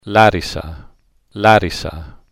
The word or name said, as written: larisa